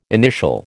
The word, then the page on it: initial: American English